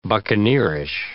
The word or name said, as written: buccaneerish